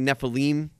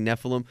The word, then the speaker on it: Nephilim: So were they, were the Nephilim, you heard them say Nephilim, so whatever, however you want to pronounce it, I say Nephilim.